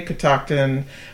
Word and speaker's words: Catoctin: Now, Catoctin is derived from a Native American word pronounced the same way, Catoctin, but it is spelled differently. The whiskey is, and the creek, and there's a mountain, is spelled C-A-T-O-C-T-I-N, where the Native American is spelled K-I-T-T-O-C-T-O-N.